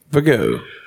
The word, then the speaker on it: Viggo: Viggo.